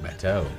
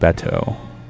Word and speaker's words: Batteaux: It looks like Bateau. – Bateau. It is spelled B-A-T-T-E-A-U-X. Maybe it's called Bateau.